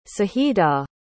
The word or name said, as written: saida